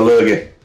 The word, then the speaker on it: lurgy: A lurgy. Did I say that right? – Yeah, the lurgy.